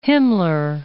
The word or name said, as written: Himmler